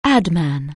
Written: adman

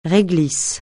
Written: réglisse

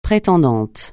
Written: prétendante

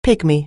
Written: pygmy

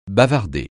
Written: bavarder

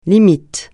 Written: limite

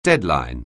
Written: deadline

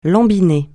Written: lambiner

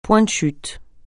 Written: point de chute

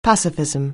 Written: pacifism

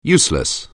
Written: useless

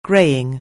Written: greying

